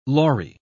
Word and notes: lory: British English

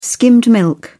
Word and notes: skimmed milk: British English

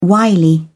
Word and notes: wily: British English

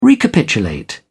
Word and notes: recapitulate: British English

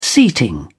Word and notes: seating: British English

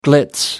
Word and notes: glitz: British English

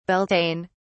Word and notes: Beltane: British English

